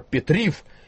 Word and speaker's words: Петрив: Тут победил Александр Петрив из Украины, как можно сразу догадаться по произношению этой фамилии, которая по-русски звучала бы как Петров.